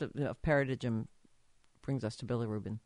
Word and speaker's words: bilirubin: It's bilirubin.